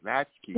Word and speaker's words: latchkey: What's the right pronunciation? – Lotsky kids. Oh, were you really? – Like a latch, yeah. – Oh, latchkey. Okay, latchkey.